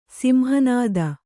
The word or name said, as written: ಸಿಂಹನಾದ